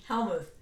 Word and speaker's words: Helmuth: Helmuth. – Helmuth. So the U is long.